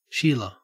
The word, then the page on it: sheila: Australian English